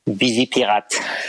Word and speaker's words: Vigipirate: Vigipirate.